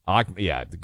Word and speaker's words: Ahmadinejad: Mahmoud Ahmadinejad or whatever he pronounces his Ahmadinejad.